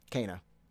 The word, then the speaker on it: Kena: Everyone now finally knows the correct way to say it. – Kena, right? Kena, got it. – Kena, 100%. Can't go wrong now.